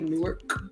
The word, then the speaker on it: Newark: Am I saying it right? Newark? – No. – I can't say it. Newark.